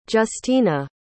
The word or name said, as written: justina